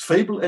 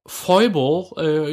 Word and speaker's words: Fable: Sagst du mal, wie du es aussprechen würdest? – Fable Anniversary. – Okay, weil ich habe immer dieses... Foible is going to be the best possible role-playing game ever und so.